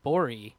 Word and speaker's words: Gborie: Go check out David Borey. It's spelled G-B-O-R-I-E. The G is silent, which is his Twitter handle.